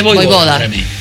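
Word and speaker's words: Vojvoda: Es como Djokovic, Boj Boda.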